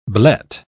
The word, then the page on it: blet: British English